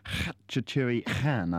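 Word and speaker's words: Khachaturian: The new single by Sky, which is called Masquerade, and that is originally by Khatchaturi Khan, I think is about is the original Armenian pronunciation, I think.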